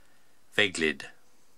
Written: Veglid

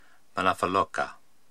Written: Manafaloka

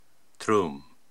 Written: Troum

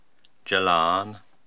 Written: Jalaan

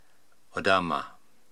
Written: Odama

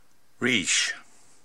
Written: Riish

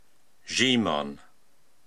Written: Zhiimon

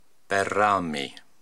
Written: Berraami

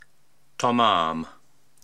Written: Tomaam

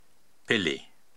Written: Pili

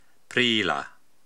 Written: Priila

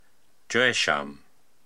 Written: Joesham

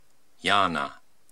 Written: Yaana

